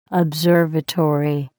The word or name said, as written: observatory